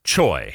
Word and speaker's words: Choi: What he said there is Choi. His name is spelled C-H-O-I.